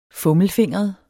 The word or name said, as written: fummelfingret